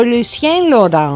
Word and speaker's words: Lucien Laurent: Ο Λουσιέν Λοάν. Μεξικάνοι. – Μεξικάνοι. – Το φάγανε. – Ναι, αφού είπα Γαλλία-Μεξικό. – Ναι. – Θα το πω με προφορά ξανά. Ο Λουσιέν Λοράν. – Μάλιστα.